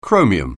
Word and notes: chromium: anglais

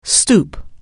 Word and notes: stoop: anglais

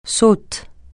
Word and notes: saute: francais

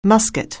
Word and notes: musket: anglais

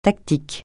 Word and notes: tactique: francais

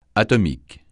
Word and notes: atomique: francais

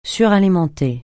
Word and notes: suralimenter: French

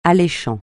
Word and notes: alléchant: francais

